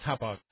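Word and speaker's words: Hauppauge: Escape is a division of Hoppage, however you pronounce them. – I think it's Hopog.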